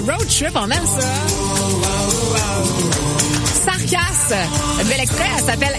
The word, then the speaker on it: Sarcass: Écrit presque comme ça se prononce, Sarkasse. S-A-R-C-A-S-S.